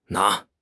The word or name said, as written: na2